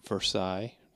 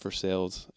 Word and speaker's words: Versailles: Versailles, to people who don't know how to pronounce it correctly. But Versailles, Indiana, to the Bel Air Drive-In.